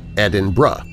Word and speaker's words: Edinburgh: Edinburgh, and in fact anywhere with the berg ending, is said bruh, as in uh in umbrella, so Edinburgh.